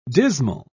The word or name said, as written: dismal